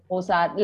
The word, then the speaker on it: Ozark: Ozark,